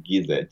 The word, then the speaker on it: Gyze: So pronounce it Gize. G-Y-Z-E is Gize.